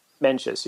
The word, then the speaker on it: Mencius: Mencius, yeah.